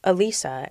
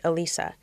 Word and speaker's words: Alisa: It's this phenomenon that I find particularly amusing where someone will ask me my name and I will say, Alisa, and they say, can you spell that? Sure, A-L-I-S-A, Alisa.